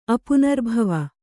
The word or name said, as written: ಅಪುನರ್ಭವ